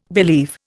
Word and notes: believe: Indian

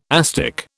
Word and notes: asdic: British English

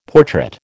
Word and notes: portrait: American English

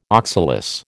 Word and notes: oxalis: American English

